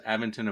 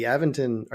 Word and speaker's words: Aventon: And the Aventon, I guess it's technically Aventon. I've been saying it wrong for years, I think.